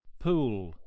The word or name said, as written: pool